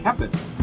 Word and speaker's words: Koeppen: Koeppen. The O is actually silent.